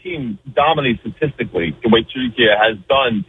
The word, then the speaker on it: Türkiye: The way Turkish people pronounce it is actually the way it said Türkiye.